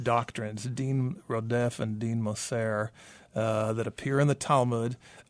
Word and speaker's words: Moser: Moser or M-O-S-E-R? – Moser. – Moser. – Yeah.